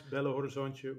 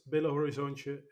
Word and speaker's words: Belo Horizonte: Did I actually pronounce Belo Horizonte right or not? – 8 out of 10. That was good.